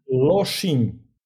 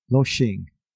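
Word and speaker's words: Lošinj: Can you tell me how to pronounce it? – Loshing. – Loshing. – Yes.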